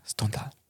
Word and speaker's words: Stendhal: Stendhal.